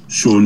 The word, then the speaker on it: Sion: Find me on Instagram on Sean James, where it's spelled, I don't know, is my name up here? Yeah, isn't it? – S-I-O-N. – Yeah, it's a kinky Welsh way, isn't it?